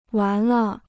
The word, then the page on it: 完了: wán le